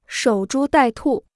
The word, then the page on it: 守株待兔: shǒu zhū dài tù